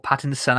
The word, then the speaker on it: Pattinson: I'm still not sold on Robert Pattinson, or Pattinson, I should say. I always say his name wrong. It's I-N as opposed to E-R in the middle of his name.